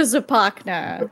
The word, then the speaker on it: Zapakna: That's how you pronounce it. – Okay. – Zapakna. – Like Tupac, so. – Right in the middle.